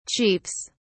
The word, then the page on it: chiefs: British English